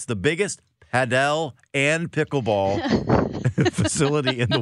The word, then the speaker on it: Padel: For Roaming St. Louis, Paddle and Pickle Club. – Padel.